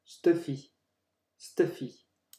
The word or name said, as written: stuffy